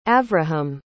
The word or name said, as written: avraham